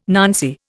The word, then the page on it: nancy: Indian